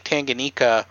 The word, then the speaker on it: Tanganyika: This river runs sort of like to the coast into Mozambique, all the way to Lake Tanganyika, which is sort of like where the Congo meets Namibia.